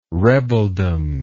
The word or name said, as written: rebeldom